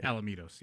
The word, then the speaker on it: Los Alamitos: Alamitos, yes.